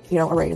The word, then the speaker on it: Array: Array is the correct way to pronounce it, and it's a beautiful brand.